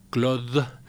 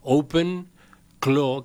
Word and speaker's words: OpenClaw: A ver, aclaremos antes de seguir, porque el nombre capaz que puede confundir, no es Claude, sino Claw. OpenClaw, que se escribe C-L-A-W, ¿no? – Es correcto.